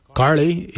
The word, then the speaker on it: giilyi: Dab is spelled D-H-A-A-B. It is a traditional knife, a dab. For boomerang, we say garli. Garli is the traditional name for boomerang. Garli is spelled G-I-I-L-Y-I, garli.